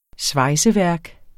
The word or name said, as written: svejseværk